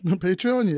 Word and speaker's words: Patreon: Did I say that word right? Patreon, yeah.